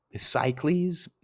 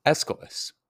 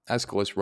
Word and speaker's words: Aeschylus: There are a series of plays written by, I always forget how to pronounce his name, Cycles? Stay tuned. Yep, very wrong. Aeschylus. Aeschylus wrote a series of plays on Agamemnon and his returning to home.